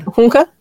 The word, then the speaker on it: Hunka: Hunka. Okay. Um, hunka. Uh, if you're going to say it correctly.